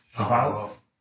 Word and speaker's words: Shavuot: Because I pronounce these B's and he pronounces these V's. So how do they say it? – Shavuot.